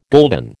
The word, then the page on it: gulden: American English